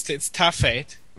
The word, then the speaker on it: Ta Fête: It's Ta Fête.